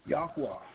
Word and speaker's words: Yahuwah: And it comes up with the name of God, of the Israelites, representing the biblical pronunciation of Yahuwah, YHWH.